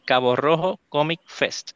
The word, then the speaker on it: Cabo Rojo Comic Fest: Pues durante el día de hoy vamos a estar hablando del Cabo Rojo Comic Fest.